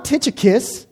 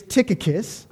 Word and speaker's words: Tychicus: As I was reminded and graciously informed by my friend, Mike Miller, a fellow Greek student, that there is no ch sound in the Greek. So it's not Tychicus, it's Tychicus.